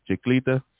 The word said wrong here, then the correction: chancleta: The chancleta. The chancleta.